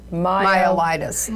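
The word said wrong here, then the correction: myeloencephalitis: Myelencephalitis. – Yeah, yes, there you go.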